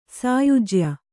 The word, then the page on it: ಸಾಯುಜ್ಯ: sāyujya; Kannada